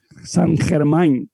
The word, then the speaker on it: Saint Germain: Saint Germain, como francés, hay que decirlo. Yo creo que se lo pronuncia bien, vale.